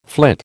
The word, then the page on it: flit: American English